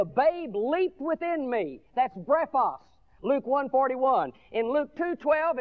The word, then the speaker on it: brephos: The word for babe in the Greek language is brephos. Brephos, B-R-E-P-H-O-S is the way we'd spell it in English.